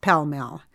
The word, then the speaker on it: Pall Mall: It comes from the popular 16th century Italian ball and mallet game, Pallamaglio, which came to England as Pall Mall, pronounced Pell Mell.